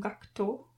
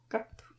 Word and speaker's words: Gackt: Meine deutschen Freunde haben früher mal Gackt gesagt, dieser, ähm, der Sänger. – Mhm. Ähm, und hier, ich glaube, die japanische Aussprache geht mehr in die Richtung Gakto. Gacto.